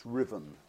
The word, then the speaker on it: Ruthven: And the writer is Scottish, and his first name is pronounced Riven, although it's spelt Ruthven, Riven Todd.